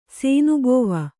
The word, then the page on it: ಸೇನುಬೋವ: sēnubōva; Kannada